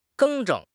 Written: 更正

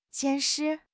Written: 监视

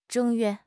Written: 正月

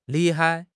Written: li4 hai5

